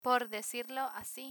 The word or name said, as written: por decirlo asi